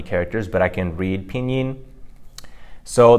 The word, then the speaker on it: Pinyin: So Pinyin is pronounced in a double first tone. So that's spelled P-I-N-Y-I-N.